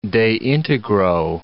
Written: de integro

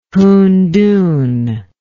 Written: huntun

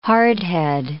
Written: hardhead

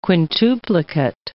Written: quintuplicate

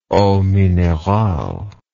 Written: eau minerale

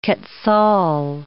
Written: quetzal

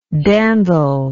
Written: dandler

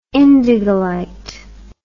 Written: indigolite

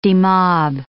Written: demob

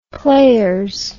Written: players

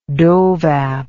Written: dovap

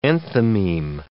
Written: enthymeme

